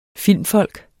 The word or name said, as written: filmfolk